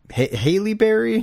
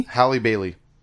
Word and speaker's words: Halle Bailey: Haley Berry? Bailey? – Halle Bailey. – Halle Bailey? – It's how you pronounce Halle Berry. Or that, she spells it the same way. So Halle Bailey? – Halle Bailey.